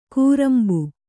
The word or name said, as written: ಕೂರಾಂಬು